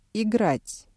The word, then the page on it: играть: Russian